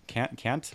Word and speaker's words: Kärntner: Mit Österreicher, Bayern, Sachsen, Österreicher gibt's noch gar nicht, Carinther oder so, aber doch, ja. Kärntner. Kärntner. – Genau.